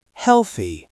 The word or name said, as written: healthy